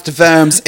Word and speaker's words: Worms: That the Council of Worms, where it's not entirely Worms. Well, that it's if you're German, but it's pronounced Worms if you are German, but it is spelt with a W.